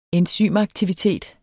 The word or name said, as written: enzymaktivitet